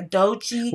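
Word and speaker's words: Doechii: Dochi, am I saying her name right? Yeah, Dochi. – With two eyes. – With two eyes, not one, two.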